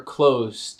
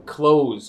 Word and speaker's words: clothes: E notem a pronúncia de clothes, roupa, aqui. Clothes, com som de Z. Clothes. Tem a mesma pronúncia quanto o verbo fechar. To close.